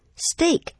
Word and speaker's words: steak: Fillet steak, steak, S-T-E-A-K, 注意到它的发音是 A 的音牛排。 Fillet, F-I-L-L-E-T, 是指菲力牛排就是指腰部那一块里肌肉非常滑嫩好吃的。